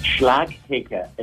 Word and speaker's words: Slajeke: Bé, fa falta una miqueta més. És Slajeke. És una mica complicat, però.